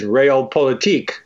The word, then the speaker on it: Realpolitik: Okay, another German word you discuss in your book is Realpolitik. Am I pronouncing that correctly? – That's how I pronounce it.